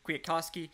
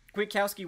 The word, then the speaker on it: Kwiatkowski: I will say that linebacker Nick Kwiatkowski, speaking of butchering names, it's spelled Koski, but it's Kowski, so just bear with me there. Kwiatkowski was getting rotated in a lot, but he was subbing with Michael Walker, not Rayshon Evans, which was surprising to me.